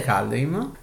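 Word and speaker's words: Kaldheim: corretta è Caldeim. Perché potevo controllarla.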